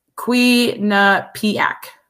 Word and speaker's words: Quinnipiac: He put the pronunciation is Quinnipiac.